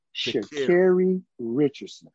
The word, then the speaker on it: Sha'Carri Richardson: Sha'Carri Richardson.